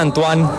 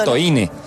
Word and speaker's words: Antoine: Sí, a mí me dicen Antoine. Pero me tocó cambiarme el nombre porque en Colombia no podía pronunciar. – Es como Antonio. – Antoine.